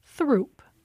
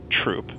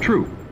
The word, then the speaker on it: Throop: Well, one of them runs through Bridgeport and other neighborhoods and looks like you might pronounce it Throop. – But most Chicagoans pronounce it Troop. So that's what we found in our research, and that's what we went with. – Troop. – So there they went with the local vernacular pronunciation.